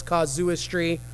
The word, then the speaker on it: casuistry: This is how it's pronounced in the books, casuistry.